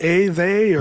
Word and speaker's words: Athey: A, they, or, and I say, well, it sounds like atheist, but we are not atheists. – That's right. – But it's Athey.